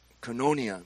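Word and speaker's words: koinonia (κοινωνία): That word fellowship is originally pronounced koinonia.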